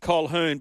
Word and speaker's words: Colquhoun: Big man, does it annoy you that Jesse Colquhoun, you pronounce it Colquhoun, but it's spelt Colquhoun. Why is that name spelt so hard?